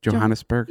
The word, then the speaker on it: Johannesburg: Am I saying it right? Johannesburg? – Yeah.